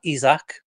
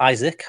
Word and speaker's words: Isak: Alexander Isak, or Isaac, however you want to pronounce it, is set to sign.